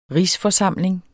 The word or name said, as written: rigsforsamling